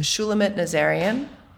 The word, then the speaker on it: Shulamit Nazarian: Shulamit Nazarian.